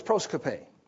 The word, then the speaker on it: proskope: The word is proskope. Proskope. Phonetically, it's be spelled P-R-O-S-K-U-P-E, I believe. P-R-O-S-K-O-P-E.